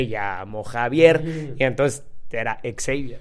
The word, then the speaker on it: Xavier: Era como el profesor Xavier, que me viene enterando que se pronunciaba Xavier... Por las pinches películas de los X-Men. Porque yo le decía al profesor Javier.